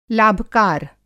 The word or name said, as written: लाभकार